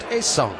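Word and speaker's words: Ha-seong: His translation of his first name in Korean is Comet. Pronounced Haesung.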